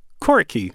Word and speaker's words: Coriky: It's a new trio from Washington, D.C., and they are called Quirky. Right? That's how you say it, right? – Spelled C-O-R-I-K-Y.